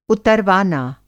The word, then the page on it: उतरवाना: Hindi